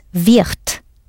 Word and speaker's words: wird: Michelle Thomas rightly sounded that final letter as a clear T, but wird is written W-I-R-D. Final D's do sound like T's.